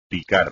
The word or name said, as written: picar